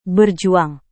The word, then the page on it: berjuang: Indonesian